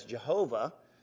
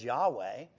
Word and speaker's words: Yahweh: Now, sometimes Yahweh is mistransliterated as Jehovah. But the actual closest pronunciation of God's name is Yahweh.